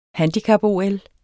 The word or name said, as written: handicap-OL